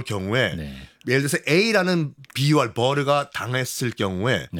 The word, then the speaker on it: bur: 그 요새를 B-U-R, bur 또는 buru 여러 가지 발음이 있는데.